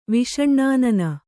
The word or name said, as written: ವಿಷಣ್ಣಾನನೆ